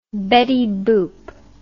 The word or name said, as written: Betty Boop